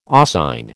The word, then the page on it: oscine: American English